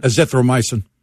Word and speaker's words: azithromycin: A more recent French study used the drug in combination with the other drug, I don't know how to pronounce it, the azithromycin. – Azithromycin.